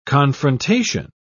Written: confrontation

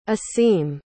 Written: aseem

